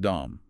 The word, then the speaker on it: DOM: And that's really the fundamental function of the Dom ladder, pronounced Dom, by the way.